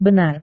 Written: benar

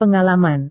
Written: pengalaman

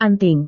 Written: anting

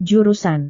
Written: jurusan

